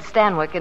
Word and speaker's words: Stanwyck: Well, that's how they said Stanwyck in England. Oh. Stanwyck, it rhymes with Zanuck.